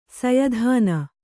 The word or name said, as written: ಸಯದಾನ